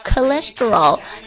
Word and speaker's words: cholesterol: 我们一起来读一下 cholesterol 胆固醇的意思啊，